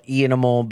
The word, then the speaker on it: iAnimal: Yeah, it's iAnimal, IANIMAL, eanimal, but it's spelled iAnimal.